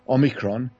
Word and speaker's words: Omicron: What's worrying about this particular variant, which is now called Omicron, that's the way it's actually pronounced, Omicron, its technical name was 11529.